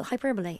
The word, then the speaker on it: hyperbole: Well, hyperbole is the correct pronunciation.